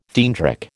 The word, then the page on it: theandric: American English